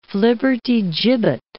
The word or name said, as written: flibbertigibbet